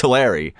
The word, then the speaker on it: Tulare: Well, Matt keeps pronouncing it wrong. It's Tulare.